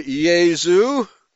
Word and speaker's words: Jesu: The Welsh and the Brits say Jesu. Remember that song? Jesu. The joy of man's desiring. When they sing that song, they pronounce it Jesu. They don't pronounce it Jesus.